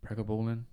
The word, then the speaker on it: pregabalin: Pregabalin? – Pregabalin.